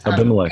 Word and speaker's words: Abimelech: His name is Abimelech. I'm so butchering that. – Yeah, Abimelech.